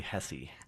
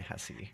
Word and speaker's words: Hesse: I always thought, I was saying it like Herman Hess, you know? – Yeah, and that's how most of America pronounce it. I'm from the Midwest, so we say Hesse.